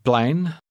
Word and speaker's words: plein: Het plein. Plein. Plein. Het. Het plein. Plein. – The middle sound in the word for square is one we don't have in English.